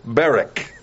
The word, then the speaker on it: Barak: Some pronounce it as the last president. But we're going to say Barak. And we're going to use that pronunciation.